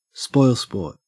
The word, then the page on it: spoilsport: Australian English